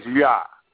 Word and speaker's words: Yah: But for sure, if we want to really know that we're pronouncing his name correctly, we can use his name right here in Psalm 68, verse 4, and it's Yah.